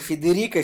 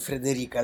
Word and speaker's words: Федерико: Один, во-первых, Федерико Филини, а не Фредерико, а другой Адриана Челентана, а не Андриана.